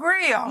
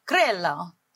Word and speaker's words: Grillo: Like, Grillo. It's Italian, so Grillo. – I like it. Italian. – My husband's Italian. – Oh, Grillo. – Yeah. – Oh. – Well, Grillo.